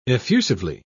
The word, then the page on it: effusively: British English